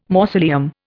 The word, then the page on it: mausoleum: Indian